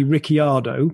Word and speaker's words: Ricciardo: So his name is actually Ricciardo, but when he came to Europe, he started pronouncing it Ricciardo because it was easier.